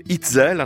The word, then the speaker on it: Eitzel: Il s'appelle Mark Itzel, hein, ça s'écrit E-I-T-Z-E-L,